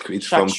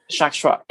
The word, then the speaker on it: Tchatchoua: So you pronounce it Chachua. It's Chachua. – From Cameroon because my parents are from Cameroon descent. So Chachua, T-C-H-A-T-C-H-O-U-A. – Chachua.